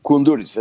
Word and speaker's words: Kunduris: Mihal Kunduris diye okunuyor galiba. – Kunduris, evet. Mihal Kunduris tabii.